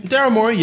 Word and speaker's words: Morey: Daryl Morey, that's how you pronounce his name? – Daryl Morey, yes, correct.